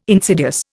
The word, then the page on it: insidious: Indian